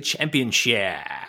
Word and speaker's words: championship: One thing I miss, having Lillian Garcia as the ring announcer so she can say championship. That woman has never pronounced the P in championship in her life.